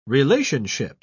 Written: relationship

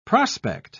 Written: prospect

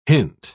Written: hint

